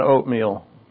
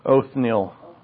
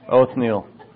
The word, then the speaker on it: Othniel: And then we start with Othniel, if that's how you pronounce his name. Not oatmeal. Othniel. It's like oatmeal with a lisp. Othniel.